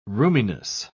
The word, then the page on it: roominess: British English